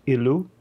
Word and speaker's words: Ilu: That was God El, or sometimes pronounced like in Ugarit, Ilu.